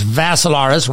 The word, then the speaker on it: Vassilaris: Go to the Big Willie 105.5 Facebook page or my profile page, Dimitri spelled with three I's, D-I-M-I-T-R-I. Last name is Vassilaris, rhymes with Assilaris, except you had a V.